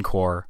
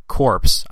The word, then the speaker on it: Corps: The most success was actually achieved by the French on the first day, aided by the British 14 Corps, not Corps, as I've been incorrectly pronouncing, so apologies for that.